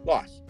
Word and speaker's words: Laos: So I contacted J-Lock and I said, J-Lock, funny story, it's not pronounced Laos, it's pronounced Laos.